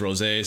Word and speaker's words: Rozay: And you know the rose, like Rosé, like the wine, is spelled R-O-S-E with the little line above it. That's pronounced Rosé.